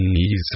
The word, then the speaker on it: Isis: Se pronuncia este mantra alargando el sonido de cada una de las cuatro letras que lo componen, Isis, repartido en dos sílabas Isis.